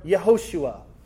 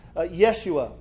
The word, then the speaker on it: Yehoshua: Or Yehoshua. That's the older pronunciation. Sometimes it's Yeshua.